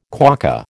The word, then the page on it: quokka: American English